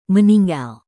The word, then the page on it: meninggal: Indonesian